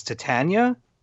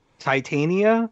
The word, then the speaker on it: Titania: But any book that includes, how do you pronounce this? Titania? – Yeah. – Titania? – Yeah. Both are fine.